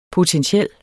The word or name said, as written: potentiel